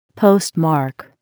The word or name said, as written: postmark